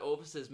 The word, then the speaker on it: orifice: How do you say it? – Orifice. – Oh, is it? – Yeah, it's O-R-I-F-I-C-E.